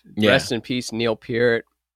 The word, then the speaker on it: Peart: Because it looks like Pert, man. Who says Peart? Yeah. Peart is stupid. But now that he's dead, I say it right.